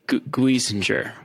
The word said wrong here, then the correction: Geisinger: Guisinger. – Geisinger. – Yeah, Geisinger. Geisinger. Yes. Geisinger.